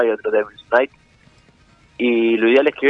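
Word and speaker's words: Devil's Nite: La banda se pronuncia Devil's Night, sí. ¿No? Porque está en inglés. – Sí. – Devil's como diablo. – Sí. – Y todo junto, N-I-T-E. Devil's Night-E. N-I-T-E. N-I-T-E.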